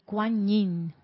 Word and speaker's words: Kuan Yin: K-U-A-N y luego aparte Y y N. Quan Yin. – Y dice que se pronuncia como si fuera una ñ, Kuan Yin.